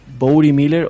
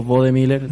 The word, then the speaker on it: Bode Miller: Eh, son uno, un esquiador americano, que seguramente lo conocerán muchos los que nos escuchan, que se llama Bowdy Miller o Bode Miller, dependiendo como cómo quieras pronunciar el nombre.